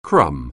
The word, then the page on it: crumb: anglais